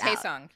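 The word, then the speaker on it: Hae Sung: And it turns out that Na Young is like used to being like number top in the class, and Haesung is like Haesung, right? I'm saying this wrong. Edit it. – It's Haesung. You're close. Haesung.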